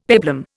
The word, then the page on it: peplum: Indian